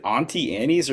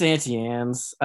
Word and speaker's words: Auntie Anne's: Is it Auntie Annie's, or is it Auntie Anne's? – It's Auntie Anne's.